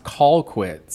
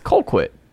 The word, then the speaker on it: Colquitt: Because that is pronounced Colquitt, sir. – It's Colquitt. – It is. That might be how it's spelled to you, but us down here folk in Georgia, we say Colquitt.